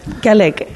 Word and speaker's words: Kalak: Kalek. – Kalek is the right way. Yeah. Written Kalak, but pronounced Kalek.